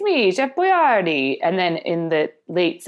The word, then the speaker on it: Boyardee: She wanted to do pasta, and I decided to do America's favorite chef, Chef Boyardee, because that's actually how you pronounce his name. – Boyardee. – Yes. So, Chef, but it's crazy because, you know, my whole life I've been calling him Chef Boyardee, and that would be French. – That is not how you say his name. His name is pronounced Boyardee.